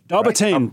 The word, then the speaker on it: Dobbertean: And with us via video call is our own personal bracketologist, Chris Dobertin. So, have I said that right? – Yes, Dobertin. – Dobertin, right. Yes.